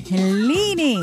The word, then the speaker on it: Helene: How do you say it? Helene or Helene? – Helene. – Helene. It doesn't look like it's said like that, but you know, what do I know?